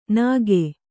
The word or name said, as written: ನಗೆ